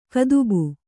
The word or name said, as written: ಕದುಬು